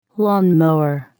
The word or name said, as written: lawn mower